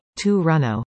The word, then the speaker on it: Toronto: The right way to pronounce it for non-natives is Toronto, although locals will usually say Toronto.